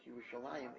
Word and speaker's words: Yerushalayim: But that is why in Tanakh, although Yerushalayim is pronounced Yerushalayim, it is always written without a yud.